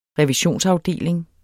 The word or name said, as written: revisionsafdeling